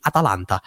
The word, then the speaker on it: Atalanta: now you're doing the Atalanta model. That's a weird way I pronounced it. Atalanta, whatever. I'll pronounce it like a douchebag properly. Atalanta.